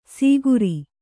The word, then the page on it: ಸೀಗುರಿ: kannada